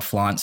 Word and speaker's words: FLAVNT: And so my clothing line is called Flaunt Streetwear. It's spelled F-L-A-V-N-T Streetwear. The V is aesthetic, not phonetic.